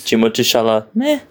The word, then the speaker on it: Timothée Chalamet: Timothy Chalamet.